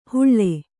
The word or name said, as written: ಹುಳ್ಳೆ